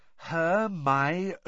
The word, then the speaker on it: Hermione: Hermione was now teaching Crumb to say her name properly. He kept calling her Hermione. – "Hermione," she said, slowly and clearly.